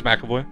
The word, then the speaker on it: McAvoy: It's James McAvoy. – Right? I thought I was saying it wrong for some reason. Yeah, McAvoy.